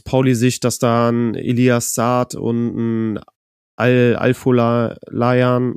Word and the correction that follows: Afolayan: Genau, Afolayan, genau, dass die...